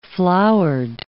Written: flowered

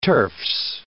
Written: turfs

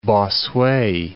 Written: Bossuet

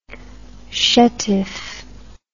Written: chetif

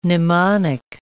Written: mnemonic

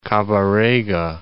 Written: Kabarega Falls

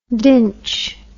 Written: dinch

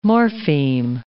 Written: morpheme

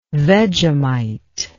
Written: Vegemite

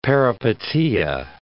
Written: peripeteia

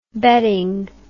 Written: betting

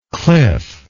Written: clifflike

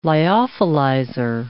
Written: lyophilizer

